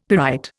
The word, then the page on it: pyrite: Indian